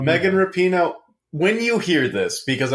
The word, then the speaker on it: Rapinoe: I do really want to say, because it's been bugging me, and I'm a huge dick for saying this, but her last name is pronounced Rapinoe. – I looked that up, and I was hoping I could keep pronouncing it wrong. – But Rapinoe.